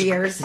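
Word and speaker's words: Gruyères: The region actually has an S on the end. The cheese doesn't. But in Switzerland, it's Gruyere's. – Gruyere's. – I mean, silent S, but.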